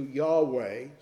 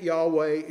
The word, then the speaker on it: Yahweh: Uh, the scholars have come pretty much to a consensus today that it is probably closer to Yahweh.